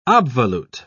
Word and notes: obvolute: British English

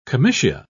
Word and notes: comitia: British English

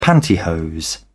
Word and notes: pantyhose: British English

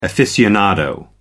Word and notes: aficionado: American English

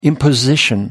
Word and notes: imposition: American English

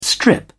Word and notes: strip: British English

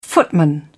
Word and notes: footman: British English

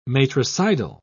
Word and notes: matricidal: British English

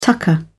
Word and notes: tucker: British English

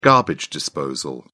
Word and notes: garbage disposal: British English

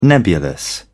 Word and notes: nebulous: British English

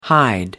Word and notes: hide out: American English

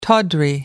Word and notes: tawdry: American English

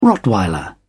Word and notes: rottweiler: British English

